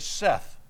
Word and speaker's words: saith: Notice it says in verse 1, these things saith he that holdeth the seven stars in his right hand. You see that word S-A-I-T-H? Most Southerners pronounce that saith, saith. The correct pronunciation is Seth. Seth.